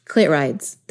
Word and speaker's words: clitorides: Clit rides. They're clit rides. – That's well, what clit, it is. Well, silent O. Yeah, silent O. Clit rides.